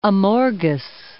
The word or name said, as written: Amorgos